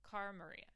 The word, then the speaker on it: Cara Maria: cara maria cara maria that's how you say it.